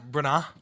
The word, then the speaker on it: Branagh: How did you pronounce it earlier? – Branagh.